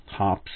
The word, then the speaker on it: Hoppe's: Now, not everyone has a huge supply of Hoppe's gun cleaning solvent, Hops, if you like to pronounce it that way, that which I've always used, and I think it's awesome.